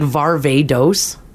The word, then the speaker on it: Varvatos: Apparently it's like Varvatos. – Varvatos? – Like Barbados. – Varvatos? But Varvatos? Oh, that sounds fancier. – Varvatos? – Varvatos. – Like Barbados. – Barbados. So the T turns into a D? – John Varvatos.